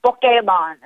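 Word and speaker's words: Pokémon: Oh wow, they're doing Pokemon Go on the trolley. – It's Pokemon, is how you say it. That's the actual Japanese pronunciation.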